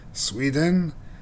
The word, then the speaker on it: Suiren: It's called Sweden, S-U-I-R-E-N. I'm doing my best to pronounce it the way it would be pronounced in Japanese.